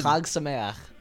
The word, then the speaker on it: Chag Sameach: Chag Sameach.